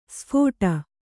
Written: ಸ್ಫೋಟ

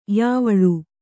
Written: ಯಾವಳು

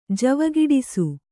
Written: ಜವಗೆಡಿಸು

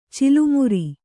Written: ಚಿಲುಮುರಿ

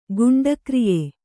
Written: ಗುಂಡಾಕ್ರಿಯೆ

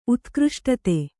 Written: ಉತ್ಕೃಷ್ಟತೆ

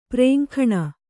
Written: ಪುರಾಂಗನೆ

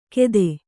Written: ಗೆದೆ

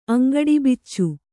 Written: ಅಂಗಡಿಬಿಚ್ಚು

